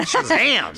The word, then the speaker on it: Shazam: And all you got to do to enter is text the keyword Shazam. Did I say it right? – Yeah, Shazam.